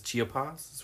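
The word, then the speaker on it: Chiapas: Because the type of coffee we're talking about is chia pods, right? Did I say it right? – I think you said it right.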